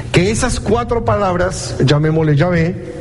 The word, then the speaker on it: Yahvé: Pero de acuerdo a los lingüistas, de acuerdo a los eruditos, Jehová es la palabra menos probable. La más probable es Yahvé. Es la más probable, la manera más probable en cómo se pronuncia.